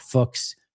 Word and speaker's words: Fuchs: And defensive assistant is Scott Fuchs, rhymes with books.